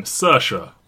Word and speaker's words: Saoirse: Saoirse, like the word inertia.